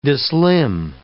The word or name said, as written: dislimn